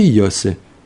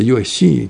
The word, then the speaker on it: Йосе: Отвечает Раби Йосе И. Пишется Йоси, часто произносится неправильно. Надо Йосе. Как Абае, Йосе. Несмотря на то, что там Юд стоит в конце.